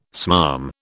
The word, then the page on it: smarm: British English